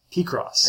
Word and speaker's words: Picross: P-Cross 3D 2. – P-Cross? – P-Cross. – How do you spell that? – P-I-C-R-O-S-S.